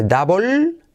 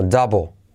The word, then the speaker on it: double: Doble no se dice double, sino double. Double. La L es casi insignificante, letra clave L.